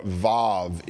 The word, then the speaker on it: vav: If you'll look at yours, right above verse 41, most of you should have the word vav, or vav is how it's pronounced from what I understand in the Hebrew.